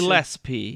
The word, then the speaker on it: Gillespie: Gillespie.